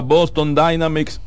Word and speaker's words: Boston Dynamics: La tecnología no se detiene, sistema del 911 y la empresa Boston Dynamics lo dije bien.